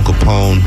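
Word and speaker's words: Kapone: And I am Tone Capone. I spell my name with a K. Y'all spell it with a C, you will not see me.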